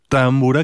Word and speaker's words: támbura: Y, por ejemplo, la primera támbura, que más bien así lo pronunciarían los húngaros, es la de Aaron Ereditch, que en parte de esta pieza también se ocupa del tambor tapán.